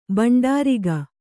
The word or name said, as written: ಭಂಡಾರಿಗ